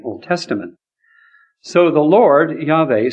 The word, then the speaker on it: Yahweh: The Lord, Yahweh, we'll talk about that word in a minute, YHWH. You can pronounce it Yahweh if you want. I pronounce it Yahweh because modern Hebrew would pronounce it that way.